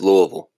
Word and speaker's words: Louisville: Are we at least pronouncing Louisville right, or is it Louisville? – No, it's Louisville.